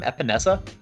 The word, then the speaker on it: Epenesa: And I still have AJ Epenesa. So, is that how you say it? Have I been butchering it this whole time? Epenesa? – Yeah.